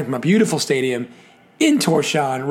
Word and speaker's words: Tórshavn: I called it Tórshavn because it looks like, it's spelled like T-O-R-S-H-A-V-N. So Tórshavn's a good way to go phonetically. I have learned through the wonders of the internet it is actually Tórshavn.